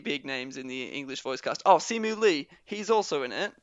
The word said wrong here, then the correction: Simu Liu: Oh, sorry, Simu Liu, I think it's pronounced.